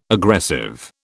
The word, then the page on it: aggressive: British English